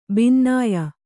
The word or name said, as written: ಬಿನ್ನಾಯ